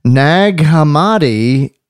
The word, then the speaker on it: Nag Hammadi: Nag Hammadi is where they found the Gnostic gospel. – Oh, wow. – Nog like eggnog. Hum-dee-dum.